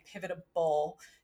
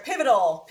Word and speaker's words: pivotal: I keep saying like pivotable instead of, I can't even pivotal. Say it right. Whatever. Pivotal, no B in there.